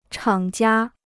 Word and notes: 厂家: chǎng jiā

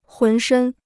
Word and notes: 浑身: hún shēn

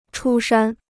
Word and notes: 出山: chū shān

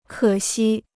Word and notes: 可惜: kě xī

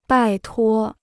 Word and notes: 拜托: bài tuō

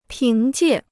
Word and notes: 凭借: píng jiè

